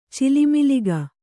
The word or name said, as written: ಚಿಲುಮಿಲಿಗ